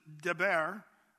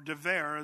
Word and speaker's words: dever: Now the word murrain, the Hebrew word is deber, or dever, as some of the Hebrews pronounce it today, dever, which means basically a deadly pestilence.